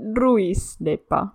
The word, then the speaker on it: Ruisleipä: 然后这个叫做 Lucy Lucy Lucy Lucy Lei 吧。 – Ruisleipä。对对对，我们芬兰文就是每一个字都要发音，就其实还蛮直白、蛮直接的。所以 Ruis 就是 Ruis 这样子。